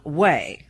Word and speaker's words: Ouai: She's now selling a pet shampoo from Way. Way is spelled, by the way, O-U-A-I, hair care products.